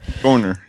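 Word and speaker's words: Görner: Gorner, yeah. It's a German Gorner name. – Yeah, very German. – G-O with an umlaut R-N-E-R.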